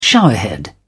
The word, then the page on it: showerhead: British English